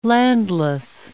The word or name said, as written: landless